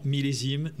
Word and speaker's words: Millésime: How do you pronounce that? – Grand Blanc Millésime.